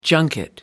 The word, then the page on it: junket: American English